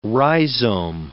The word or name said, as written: rhizome